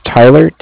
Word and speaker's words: Tyler: My name is Tyler, T-Y-L-E-R. And I'm calling what company? – Cardiff. It's spelled C-A-R-D-I-F-F, as in Frank Frank.